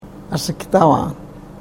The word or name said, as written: asiktavaan